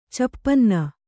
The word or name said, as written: ಚೌಪನ್ನ